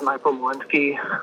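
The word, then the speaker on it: Michael Molinsky: My name is Michael Malinsky.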